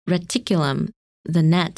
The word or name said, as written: Reticulum